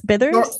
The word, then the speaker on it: Bither: Doris Byther? – Doris Byther, B-I-T-H-E-R.